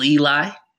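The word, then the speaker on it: Ely: My handle is Real Eli with my first name, which is spelled E-L-Y, a little different than most of them.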